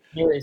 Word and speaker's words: Flurry: Once again, I hope I'm not butchering the name, but I think it's Flurry. – Flurry sounds right, yeah.